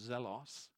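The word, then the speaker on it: Zelos (ζῆλος): How is it pronounced? Zelos.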